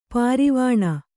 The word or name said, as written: ಪರವಾನೆ